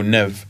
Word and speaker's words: Nevve: I don't know how you pronounce it. Is it N-E-V-V-E? – Oh, Nev. – Nev, that.